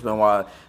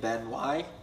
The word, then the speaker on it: Benoit: Bring up while we're talking about Chris Benoit. Vice did a documentary they recently released. – That's how you pronounce his last name, Benoit. – Benoit. He's Canadian. – French. – He's French Canadian.